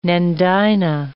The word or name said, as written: nandina